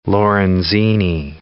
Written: ampulla of Lorenzini

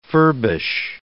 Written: furbish

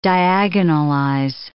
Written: diagonalize

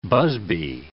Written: busby

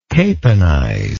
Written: caponizer

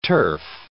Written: turf

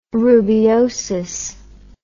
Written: rubeosis

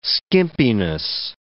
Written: skimpiness

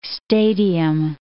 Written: stadium

